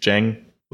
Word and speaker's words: Zhang: I think Zhang is pronounced Zhang with a J.